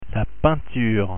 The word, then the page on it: peinture: French